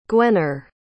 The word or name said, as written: gwener